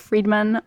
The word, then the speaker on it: Fridman: Friedman, I didn't pronounce D,